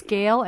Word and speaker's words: Gail: It's Gail Ambrosius. – And how do you spell that? G-A? – G-A-I-L. – E-I-L.